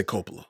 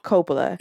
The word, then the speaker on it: Coppola: I say Coppola. – Coppola.